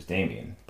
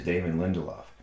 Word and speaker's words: Damon Lindelof: And in fact, Damon Lindelof, who I sometimes incorrectly pronounce as Damien, but have learned to try to do my best to say it the right way the first time, and that is Damon Lindelof,